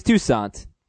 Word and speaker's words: Toussaint: or Toussaint or Toussaint, however you want to pronounce it. – Toussaint. – Because I'm not sure. – It's Toussaint.